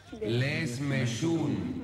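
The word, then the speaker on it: lehmeyún: Y desmeyún. – ¿Qué? Contame bien, ¿cómo se dice? – Desmeyún. – Repitamos todos. Lesmeyun.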